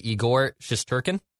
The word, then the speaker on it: Igor Shesterkin: And I think I can get this last name because I wrote down the pronunciation. – Yep. – It's the New York Rangers goalie, Igor Shesterkin.